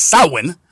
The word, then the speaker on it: Samhain: And there's perhaps nothing more representative of this than the ancient Celtic festival of Samhain, pronounced Samhain, which was celebrated throughout Ireland, the United Kingdom, and northern France, and is believed to be at least 2,000 years old.